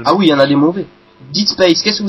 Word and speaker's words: Dead Space: Dans Dead, il y a écrit D-E-A-D. Tu dis Dead Space. Ça marche mieux.